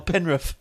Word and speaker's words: Penrith: Didn't I tell you how to say the word properly? – Oh, Penrith. Sorry.